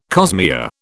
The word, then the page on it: cosmea: British English